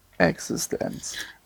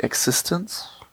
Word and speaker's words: eXistenZ: Ist es nicht Existence? – Wenn es Englisch wäre, ja. Also Existenz ist der Name von einem Computerspiel in der Zukunft. Und weil das halt cool ist, schreibt man das mit Z hinten statt mit CE.